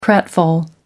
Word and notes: pratfall: American English

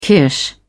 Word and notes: kirsch: British English

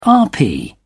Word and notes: rp: British English

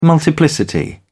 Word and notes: multiplicity: British English

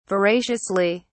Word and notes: veraciously: British English

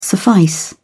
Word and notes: suffice: British English

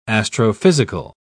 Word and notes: astrophysical: British English

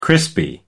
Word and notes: crispy: American English

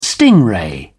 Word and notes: stingray: British English

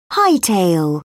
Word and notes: hightail: British English

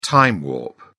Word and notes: time warp: British English

